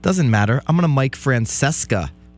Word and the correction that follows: Francesa: Or is that how you say his name? Mike Francesa style this show.